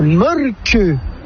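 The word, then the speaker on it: Mölkky: Inventé en Finlande en 1996, ce jeu de quilles s'écrit M-O-T-R-E-M-A-L-K-K-Y. – Ça s'écrit molki, mais ça se prononce molke. – Le molke. – Plutôt un E qu'un E, mais pas un U. Même ça fait molke.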